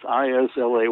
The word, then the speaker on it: Islay: Everybody pronounces it Islay. It's not. It's Islay, but, like most Gaelic words, they're not pronounced like they look, but it's Islay. Islay is an island off of the western coast of Scotland, and it's foggy and it's cold.